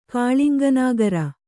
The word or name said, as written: ಕಾಳಿಂಗನಾಗರ